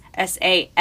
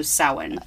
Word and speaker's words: Samhain: Yeah, there's like all these, like, if you have an H next to another consonant, it changes the way you pronounce it. Like Samhain, which is Samhain. Yeah, yeah. The MH is a W sound, so Samhain.